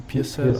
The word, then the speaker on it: Pearsall: Ricky Pearsall, ich weiß gar nicht, schreibt man Pearsall aus? – Pearsall.